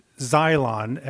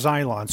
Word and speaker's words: xylon: The Greek word there for cross, it's xylon, X-Y-L-O-N.